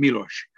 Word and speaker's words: Miloš: I hope that my pronunciation is okay. I've checked in with one Czech friend, so I hope it's not too bad. – That's all I hear. They all call him Miloš.